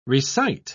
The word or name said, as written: recite